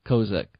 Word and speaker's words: Kozik: No, that's quite right. Yes, it's Kozik. – Okay, Kozik.